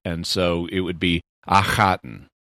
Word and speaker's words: Akhaten: So the problem is in English, we don't have the H sound in standard English. And so we represent it with KH. But the doctor is splitting it into two separate consonants, Akhaten.